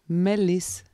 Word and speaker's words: Malice: Malice. – Melis. M-A-L-I-C-E. – Mhm. Mhm. E. Ah, Englisch ausgesprochen. Melis. Okay.